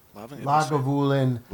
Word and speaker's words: Lagavulin: I'm a big fan of Nick Offerman and his love for, I always pronounce, Lavengul? – Lagavulin. – Lagavulin.